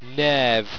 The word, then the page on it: knerhve: Owstwecian